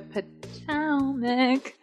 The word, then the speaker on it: Potomac: The O, the second O is the emphasis. Potomac. – That helps me so much. – The Potomac.